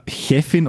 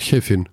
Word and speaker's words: Chefin: Folgt auf das Ch am Wortanfang aber ein sogenannter vorderer Vokal, also ein E oder I, dann wird es als ein weich zischendes Ch ausgesprochen, China. – Also Chefin oder Chirurgin, aber das sagt doch keiner.